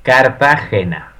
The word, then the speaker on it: Cartagena: There is Drupal Camp Columbia in Cartagena. I can't say it. You have to say it properly. – Cartagena.